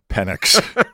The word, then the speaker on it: Penix: Penix. – His last name is spelled P-E-N-I-X.